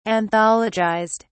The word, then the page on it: anthologized: British English